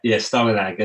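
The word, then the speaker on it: Stålenhag: And what you may not know is this is based on originally some photos by Simon Stalin. I'm going to say his name wrong, I know. – Huge fan. – You can help me. Stalinag? – Yeah, Stalinag. And his work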